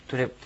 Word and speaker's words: トゥレップ: とでもないし、つでもないです。トゥという。カタカナで書くときは、とに丸をつけて書くことが多いんですね。もしくはとにちっちゃいをつけて書きます。日本語にはない発音なんで、ちょっと、難しいかもしれません。トゥレップですね。